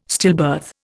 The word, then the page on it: stillbirth: Indian